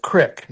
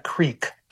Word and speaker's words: Creek: We pronounce it Crick, not Creek.